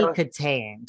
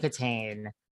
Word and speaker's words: Kitaen: Tawny Kitaen. Tawny Kitaen.